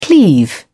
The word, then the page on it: cleave: British English